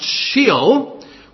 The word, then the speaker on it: Sheol: This is the equivalent to an Old Testament word, I think, that is pronounced Sheol, which refers to the place of departed spirits in Old Testament times.